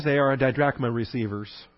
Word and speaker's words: didrachma: The Greek wouldn't use a long I sound. It would use a shorter I sound, didrachma. accent on the first syllable.